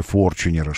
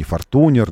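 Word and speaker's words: Fortuner: Тойота, которая целый тренинг устроила по произношению Форчунера, что он не Фортунер, не Фортунер, а Форчунер. Вот.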